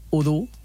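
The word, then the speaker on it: ho du: O du hauria de ser udu, d'acord? Que és diferent que duro, per exemple.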